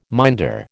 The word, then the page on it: minder: American English